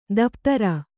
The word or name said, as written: ದಪ್ತರು